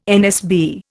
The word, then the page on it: NSB: Indian